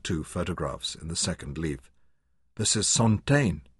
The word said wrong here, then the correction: Santaine: He pronounced it in the English way. "Her mother." Anna touched the other. "This is Santaine." She corrected his pronunciation.